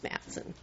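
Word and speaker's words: Mathson: It's M-A-T-H-S-O-N. – Okay. – Pronounced Matheson.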